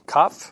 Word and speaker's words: Kaph: Kaf, I believe, is the pronunciation, K-A-P-H.